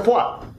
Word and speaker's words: foie: Eh, la foie, la comida foie, se escribe con las tres vocales que no se pronuncian. Se escribe foie y se pronuncia foie.